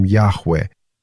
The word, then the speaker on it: Яхве: Само имя Бога, которое выражалось, записывалось 4 буквами, которые нельзя было произносить, были буквы, которые соответствовали звукам «я-ха-ва-ха». От этого мы получаем Яхве. Но читали евреи как Адонай, потому что вот это имя Божие просто нельзя было произносить.